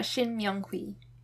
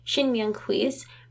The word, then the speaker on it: Shin Myung-hui: How do you say that? Myung-hui? – I think it's, yeah, Shin Myung-hui. – Okay. Shin Myung-hui's presidential candidate press conference.